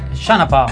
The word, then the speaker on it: Sean Paul: però dobbiamo pronunciarlo in maniera corretta. – Shanapa. – Questa è la pronuncia.